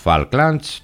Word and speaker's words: Falklands: Μπορεί να μην είχαν ξεχάσει τα Falklands στην Αργεντινή. Γράφονται με A και προφέρονται O.